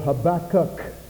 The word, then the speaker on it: Habakkuk: It's pronounced like tobacco, only it's called Habakkuk with a K on the end.